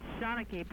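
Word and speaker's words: Shanachie: How do you say the name of the record label? – It's Shanachi, but we'll forgive you if you say Shanachi, Shananachi, Shanananananachi. We'll take anything.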